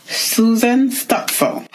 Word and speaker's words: Susan Stutzel: My guest today is mindset and productivity coach, Susan Stutzel.